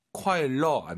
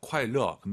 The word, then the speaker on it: 快乐: 快乐 안 돼요. 르어예요. 快乐.